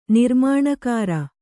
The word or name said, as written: ನಿರ್ಮಾಣಕಾರ